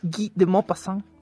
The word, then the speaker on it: Guy de Maupassant: Guy de Maupassant.